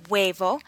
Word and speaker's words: huevo: Words like hueso and huevo begin with HU, and the HU is pronounced like a W. Hueso.